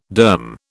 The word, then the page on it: derm: British English